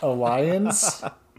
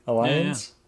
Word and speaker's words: Allianz: Alliance. A-L-L-I-A-N-Z. Alliance.